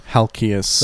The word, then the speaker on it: Halkias: Alex opened for Stavros Halakis. Is that how you say it? How do you say his last name? – Halkias. – That's what I said. – No, you said Halakis. – I said Halkias.